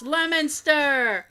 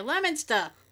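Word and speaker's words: Leominster: Leo Minister? It's Lemonster. oh my god. – Or Lemonster. – Yeah, if you're from here, Lemonster.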